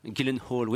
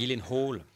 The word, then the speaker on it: Gyllenhaal: Gyllenhaal. Oui, je me souviens de ça. Euh, c'est c'est Gyllenhaal.